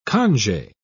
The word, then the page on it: conge: British English